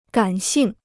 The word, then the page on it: 感性: gǎn xìng